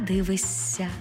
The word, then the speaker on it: дивишся: Наприклад, не «дивишся», а «дивися». Не «смієшся», а «смієсся».